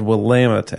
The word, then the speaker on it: Willamette: In Oregon, the valley is called Willamette, actually. – Oh, you are correct, yes. Actually. – So I'm just protecting you so you don't get emails from some crazy craft beer listener saying you pronounced Willamette wrong.